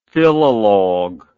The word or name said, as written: philologue